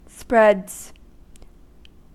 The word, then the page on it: spreads: American English